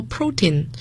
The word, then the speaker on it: protein: OK， the next one is 蛋白质，我们叫 protein，要注意它的发音，重音在第一个音节，蛋白质。